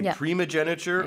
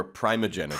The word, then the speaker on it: primogeniture: You can say primogeniture or primogeniture. – Primogeniture. – You can say both.